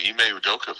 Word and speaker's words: Ime Udoka: Ime Udoka,